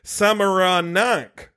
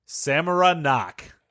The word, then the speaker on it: Samaranch: Oh, there might be an extra syllable. Samaranch. Okay. – Samaranch.